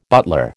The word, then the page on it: butler: American English